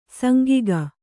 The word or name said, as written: ಸಾಂಗಿಕ